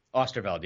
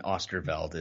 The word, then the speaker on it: Oosterveld: Oosterveld, yeah. – Oosterveld. Depends if yeah, there's a few versions. – Yeah. – But Ben Osterfeld is what I do.